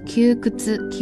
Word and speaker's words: 窮屈: 汉字是比较难写的，它是一个穷穷的呃日语的一个写法，加上一个屈委屈的屈，念作 q くつ q くつ。